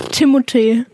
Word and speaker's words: Timothée: Einfach Timothée.